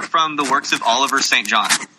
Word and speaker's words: St. John: So when you see St. John, it's pronounced St. John.